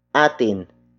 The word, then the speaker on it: atin: Word for word it's lumayo mula sa atin ang malas.